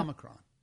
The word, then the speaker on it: Omicron: Omicron. Did I say it right? – You said it right. – I said it right. Yeah. Omicron.